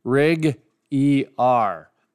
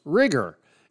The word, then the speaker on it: RigER: Of course, I love the name Rig ER. Now, ER is capitalized, but it's pronounced rigger. And yeah. when I first saw it, I thought rig ER, but of course I got corrected.